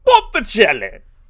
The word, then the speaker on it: Botticelli: It's a painting done by Sandro Botticelli. And that's how you have to pronounce it. Botticelli!